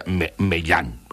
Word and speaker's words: Meillant: Doncs és una firma francesa que es diu Meillant, que s'escriu Meillant. I es pronuncia Meillant, perquè no hi ha dubte aquí.